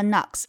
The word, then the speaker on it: Knox: o x 发的是 ox，那么 fox、 box 和 knox 里面都出现了 ox，注意 knox 的 k 不发音。